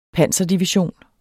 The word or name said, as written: panserdivision